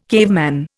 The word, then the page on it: caveman: Indian